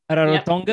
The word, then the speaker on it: Rarotonga: And then you told me about an island that you recently had an excursion to, an island called Rarotonga. Do I pronounce it correctly? Rarotonga, yep, sure.